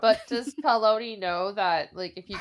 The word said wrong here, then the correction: Paolini: Now, how do I say his name? Paloni? – Isn't it Paolini? Yeah, Paolini.